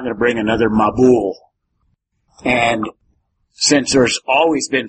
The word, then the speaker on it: Mabul: The Hebrew word, and since you don't know Hebrew, that doesn't mean anything to you, but it's pronounced Mabul. So if you wanted to transliterate it, it would be M-A-B-U-L, Mabul.